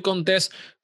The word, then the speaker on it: Contés: Mi nombre es Miguel Contés con acento en la E y nos vemos la próxima.